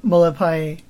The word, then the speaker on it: Molepae: But I remember he told me that it rhymed with Vavai. So I think it's Molepae.